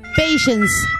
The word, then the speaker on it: patience: Patience, patience. Con ese al final, patience.